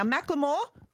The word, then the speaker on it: Macklemore: Now, Macklemore, that's how you pronounce it, made the announcement on Instagram, pointing out it wasn't actually Ed who wanted to drop him.